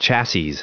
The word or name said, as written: chassis